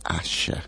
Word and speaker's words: Asa: Su nombre se escribe A-S-A, pero se pronuncia Asha.